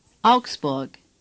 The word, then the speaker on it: Augsburg: Augsburg. – Say. – Augsburg. – The letters AU are pronounced au, as in cow. Say. – Augs. Burg. – As in. – Hamburg. Augsburg.